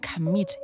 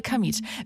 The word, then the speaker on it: Kamieth: Claudia Kanit. Kamit. Ich kann euch meinen eigenen Namen falsch aussprechen. Claudia Kamit.